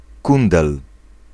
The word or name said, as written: clébard